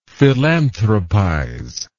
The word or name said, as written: philanthropize